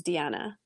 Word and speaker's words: Diana: It's D-I-A-N-A, but it's the Spanish pronunciation, which is Deanna.